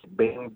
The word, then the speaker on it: Byng: Lieutenant Colonel, I believe you pronounced Bing, B-Y-N-G.